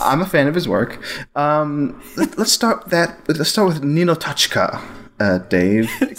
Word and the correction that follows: Ninotchka: So close. You're getting closer. You're getting closer to the right pronunciation. It's Russian, so yeah. Ninotchka. – Ninotchka.